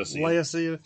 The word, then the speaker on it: Laodicea: So how I read this is that when you go back and study ancient Israel in context, and how do you say that, Laodicea? – Laodicea.